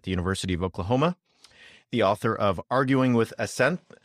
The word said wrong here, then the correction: Aseneth: Aseneth, I always say that wrong, Arguing with Aseneth,